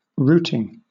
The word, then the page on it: routing: British English